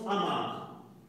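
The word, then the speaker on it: Amar (אמר): Il y a une diapositive qui, c'est l'hébreu, c'est A, qui se prononce Amar, qui veut dire parler, dire, prononcer Donc c'est bien quelque chose qui est audible.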